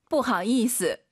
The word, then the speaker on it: 不好意思: 不好意思. – 이런 발음은 사자성어처럼 그냥 빠르게 발음하셔야 됩니다.